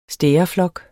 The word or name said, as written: stæreflok